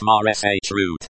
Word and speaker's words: маршрут: The word, route, in Russian is маршрут. Pronounced marsh root.